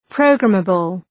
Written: programmable

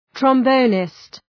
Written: trombonist

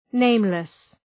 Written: nameless

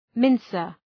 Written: mincer

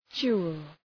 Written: jewel

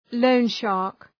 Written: loan shark

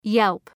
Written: yelp

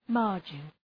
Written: margin

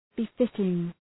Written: befitting

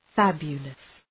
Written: fabulous

